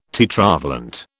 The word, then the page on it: tetravalent: British English